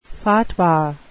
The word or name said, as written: fatwa